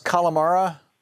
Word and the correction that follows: Kalorama: neighborhood? – Kalorama neighborhood. – Kalorama, thank you.